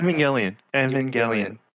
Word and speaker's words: Evangelion: Anyway, so back to Evangelion. Is that how you say it? – Evangelion.